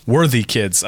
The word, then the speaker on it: Worthikids: Worthy Kids.